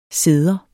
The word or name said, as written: ceder